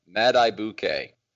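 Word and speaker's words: Madubuike: Accent on the first syllable, Madibuke.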